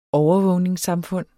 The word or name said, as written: overvågningssamfund